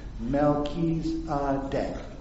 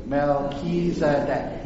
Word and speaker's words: Melchizedek: See, I even spelled it out phonetically there, because everyone has to. Melchizedek. Say that with me. Melchizedek. Now you got it forever.